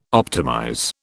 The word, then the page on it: optimize: British English